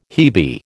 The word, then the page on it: hebe: American English